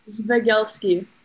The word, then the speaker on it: Zvigelsky: It's Zvigelsky. So pretty much the way you said it, you just said it weird.